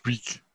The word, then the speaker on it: Reek: Reek, Reek. Rhymes with Leak.